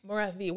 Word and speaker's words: Morathi: Morathi.